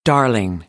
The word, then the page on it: darling: American English; ɑːr